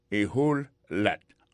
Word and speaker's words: a whole lot: A whole lot, et me plaît le prononcer, c'est A pour qu'on te parle. Whole, c'est W-H-O-L-E, lat. Et lat, a whole lat.